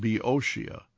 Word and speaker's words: Boeotia: The ancient Greek state of Boeotia is pronounced Boeotia.